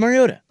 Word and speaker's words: Mariota: Why were they trying so hard to pronounce Mariota? – I don't know. – It's Mariota.